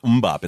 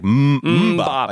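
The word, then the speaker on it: MMMBop: Did I pronounce that wrong? It's not Umbop. It's like Umbop by Hanson.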